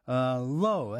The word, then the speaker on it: Lau: And then we heard a young Scottish group, Low, that's spelled L-A-U, doing Ghosts, and then followed by a tune called It's Hard to Seem to Be Okay When You Are Not.